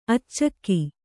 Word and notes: ಅಚ್ಚಕ್ಕಿ: accakki

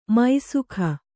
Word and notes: ಮೈಸುಖ: kannada

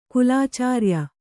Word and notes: ಕುಲಚರ್ಯೆ: kulacarye

